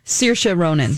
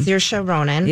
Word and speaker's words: Saoirse Ronan: Saoirse Ronan. – Saoirse Ronan.